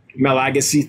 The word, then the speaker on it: Malagasy: Oh, is the Madagascars, is the Malagasy, or yeah. How do you say that? Okay. Malagasy, thank you.